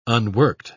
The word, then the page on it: unworked: British English